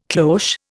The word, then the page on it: cloche: Indian